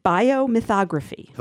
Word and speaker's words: biomythography: Could you say that word again? – Biomythography. Okay. Am I saying that right? yes.